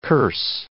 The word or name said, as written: curse